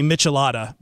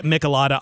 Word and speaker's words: Michelada: Well, as we just talked about before, apparently Monty had to help me correct because I had never heard the correct way to say Michelada before the show either. – No, but now you're saying it right. You were saying like Michelob in there. – I'm going to blame Petros and Money here in LA who say it Michelada on their show.